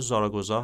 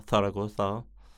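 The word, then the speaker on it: Zaragoza: شهر زاراگوزا هست، یا به قول اسپانیایی ها تاراگوزا، چون زه رو عربی تلفظ میکنن و این به خاطر همون تحت تاثیر اون داستان حضور اعراب در اسپانیا هست که ما خیلی از واژه ها هم داریم که از زبان عربی وارد اسپانیایی شده.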